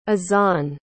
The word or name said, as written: azzan